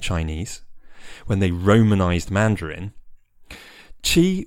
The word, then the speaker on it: qi: and in which qi is spelt qi, pronounced with a fourth tone in Mandarin, a falling tone that sounds a little bit like you're saying it whilst putting your foot down.